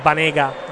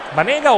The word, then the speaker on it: Banega: Il rigore di Banega al 91esimo. Mönchengladbach 4, Siviglia 2. Banega o Banega nella pronuncia, sì.